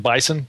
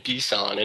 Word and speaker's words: BSON: You know, one of the interesting aspects of how you guys store data in Mongo is, I believe this is the correct pronunciation, Bison, B-S-O-N. Is that right? – Yeah, so I've been saying it BSON, and around here we've been saying it BSON, but I think that's probably open to interpretation.